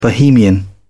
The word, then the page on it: bohemian: British English